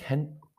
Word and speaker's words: Gent: For Partizan in the Conference League, they have been drawn against Hent. I think the G is silent in it. The Belgian side that's spelled G-E-N-T.